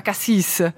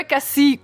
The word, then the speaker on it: Cassis: Axel, è quello che è successo anche oggi con Emanuela, che ci ha addirittura è stata molto precisa, ha detto Cassis, non si pronuncia Cassis. – Si scrive Cassis. Come il consigliere federale. – Esatto, Cassis, però è Cassì, quel posto vicino a Marsiglia, magnifico tra l'altro.